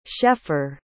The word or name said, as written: schaeffer